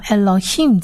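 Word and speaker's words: Elohim (אלהים): 紧接着我们看第三个字，第三个字如果听众朋友已经从第一集到现在的话，应该知道这个 Elohim 其实就是上帝的意思，上帝的意思。那 Elohim 呢，我们来看一下 Elohim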